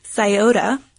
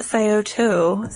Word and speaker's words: Scioto: We won't relive the wrongness, but the correct pronunciation is Scioto. Or maybe even Scioto.